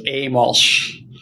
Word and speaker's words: Amolsch: Amolsch.